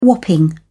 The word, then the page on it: whopping: British English